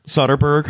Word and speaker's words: Soderbergh: Soderbergh.